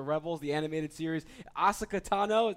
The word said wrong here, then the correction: Ahsoka Tano: that's the right way to pronounce it? – Oh, no, it's hard. It's Ahsoka Tano. – Ahsoka Tano, thank you. – That's okay. Everyone mispronounces it.